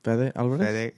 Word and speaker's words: Fede Alvarez: Fede Alvarez.